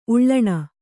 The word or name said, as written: ಉಳ್ಳಣ